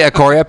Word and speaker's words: Ecoria: Ecoria is Echo with RIA at the end, and Paisley is my last name because it's like the most print.